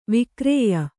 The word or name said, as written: ವಿಕ್ರಯಿ